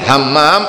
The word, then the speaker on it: Hammam: Hammam adalah seperti itu tulisannya. Tapi saya katakan tadi tidak lumrah biasanya. Ha, Hammam begitu, karena orang Indonesia kalau mengucapkan ha itu nanggung.